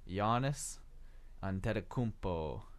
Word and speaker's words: Giannis Antetokounmpo: We butchered the name, as everyone else does, of Milwaukee Bucks young star. I don't know what I said, but 2 weeks ago, but here it is. Giannis Antetokounmpo.